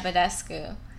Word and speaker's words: Badescu: And I don't know how to say his last name. Mario Badescu? – Yeah, Badescu.